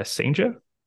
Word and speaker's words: Cijntje: It looks like it's pronounced uh, Sanja. We're going to yep. roll with that.